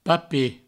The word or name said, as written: papet